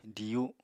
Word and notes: diyu: apatani

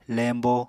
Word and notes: lembo: Apatani

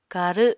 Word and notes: karw: Apatani

